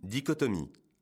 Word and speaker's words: dichotomie: Dichotomie. Aujourd'hui, notre mot du jour est dichotomie. Attention, le son co s'écrit C, H, O.